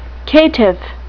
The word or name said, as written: caitiff